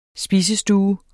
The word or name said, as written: SPISESTUE